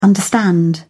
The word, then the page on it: understand: British English